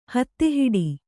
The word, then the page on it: ಹಾದಿಹಿಡಿ: hādi hiḍi